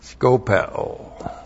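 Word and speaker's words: Skopeo (σκοπέω): And then we have skopeo. S-K-O-P-E, long O. Skopeo.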